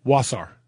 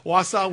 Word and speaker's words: Wausau: Wausau. – Get rid of that R. – Wausau, Wisconsin.